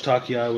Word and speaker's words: Takei: I heard George Takei was a creep.